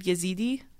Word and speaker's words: Yazidi: So according to Kurdish news outlet ANF News, the village is primarily for women whose families suffered violence by ISIS, which massacred Yazidi. Did I say that right? – Mm-hmm. – They massacred Yazidi men and raped and tortured thousands of women.